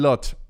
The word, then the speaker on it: Lochte: Otro escándalo fue el de Ryan Lotz. Ryan Lotz, es que no sé pronuncia, Lochte, dice aquí, pero es como Lost.